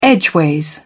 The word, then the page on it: edgeways: British English